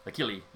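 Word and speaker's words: Achille: Achilles, yes. It's the Italian pronunciation. Sorry, Achilles. No, no S on the end.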